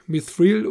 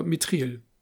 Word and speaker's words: Mithril: Und er hat einen Investmentfonds, der nennt sich, je nachdem, wie man es aussprechen mag, Mithril oder Mithril.